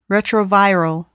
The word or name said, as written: retroviral